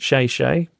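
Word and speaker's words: Shay Shay (谢谢): She signs off by saying Shay Shay means thank you. It's pronounced Shay Shay, kinda.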